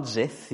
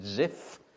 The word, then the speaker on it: Ziph: Not Zith, you've got to be careful how you pronounce it, Ziph.